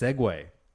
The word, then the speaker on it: segue: Someone finally fucking did it because I say the word SEG all the time, which is my interpretation of the word S-E-G-U-E. So how do you think that word's pronounced? – Seg. – No. That word is pronounced Segway. S-E-G-U-E is pronounced Segway.